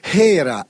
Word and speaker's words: Hera: Albert Hera, ecco, si pronuncia così con l'H aspirata,